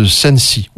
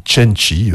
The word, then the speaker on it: Cenci: C'est l'histoire de Béatrice Cenci ou Cenci, vous prononcez comme vous voulez.